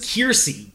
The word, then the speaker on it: Kiersey: So if you say her name wrong one more time with her name in front of you, I'm gonna kill myself. – Kirstie Clemons? – Kiersey. K-I-E-R-S-E-Y. – I'm looking at Kiersey Clemons.